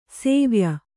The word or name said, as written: ಸಿವಾಯಿ